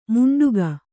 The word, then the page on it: ಮುಂಡುಗ: Kannada